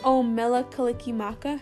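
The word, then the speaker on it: Mele Kalikimaka: Mela Kalikimaka.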